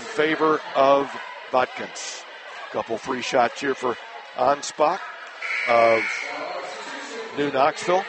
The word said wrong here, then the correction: Anspach: Actually, the correct pronunciation is Anspaugh.